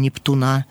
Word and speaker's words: Нептуна: Шелест листвы мне напомнит былое, непостижимо времен глубина, бронзовый бюст пионера-героя, мероприятие, день Нептуна. именно так, с ударением на этом слоге последнем, промолвит физрук в маленьком лагере с солнечным летом возле озер и ветлужских излук.